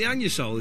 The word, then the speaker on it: Anusol: OK, yes, the anusol, as it's actually pronounced, isn't that satisfying.